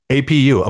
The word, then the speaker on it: Apu: APU, Apu.